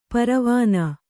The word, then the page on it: ಪರವಾನಾ: Kannada